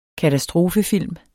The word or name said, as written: katastrofefilm